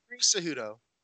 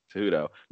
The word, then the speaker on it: Cejudo: Cejudo. – Cejudo.